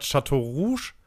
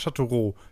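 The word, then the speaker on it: Châteauroux: Und das ist halt aus der Stadt Chateau Rouge. Ist es Chateau Rouge mit X am Ende oder Chateau Roux? Wie wird es ausgesprochen? Äh, wenn X am Ende ist, Roux okay, Chateauroux, ähm, auch ein kleines äh, Arbeiterdörfchen quasi, äh, Arbeiterstädtchen.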